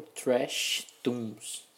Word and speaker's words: Treshtoons: Então, o canal é o Trash Tunes. Se escreve T-R-E-S-H-T-O-O-N-S no YouTube.